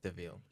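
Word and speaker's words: De Vil: And it's spelled devil. – Yes. – But it's pronounced DeVille.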